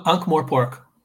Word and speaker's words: Ankh-Morpork: They're selling Discworld Ankh-Morp. Ankh-Morp? Is that how you say it? – Ankh-Morpork. – Ankh-Morpork.